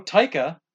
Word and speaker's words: Taika: I like Taika Waititi. I can't. – Taika Waititi. – There's no O in that. – Taika titties, no. – There's no O. – But you said Taika. – No, Taika.